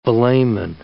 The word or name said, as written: velamen